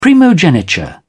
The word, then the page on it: primogeniture: British English